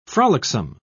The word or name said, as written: frolicsome